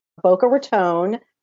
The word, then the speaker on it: Boca Raton: Yes, and you should know that because you're in Florida, but yes, it's Boca Raton, long O, not like rattan, like right. The furniture.